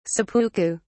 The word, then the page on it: seppuku: British English